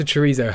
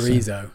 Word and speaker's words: chorizo: Let's go back to chorizo. How do you pronounce it? – Chorizo. – Like, you've got a lisp in the middle of that. Chorizo. – Well, basically, they said it's like a TH.